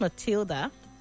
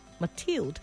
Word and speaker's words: Mathilde: Not Matilda. Mathilde. I mean, it's a French name. So it's spelled M-A-T-H-I-L-D-E. In French, it's pronounced Mathilde. Yeah, it's not an English name. D as in D-E. Canier as in C-A-G-N-Y.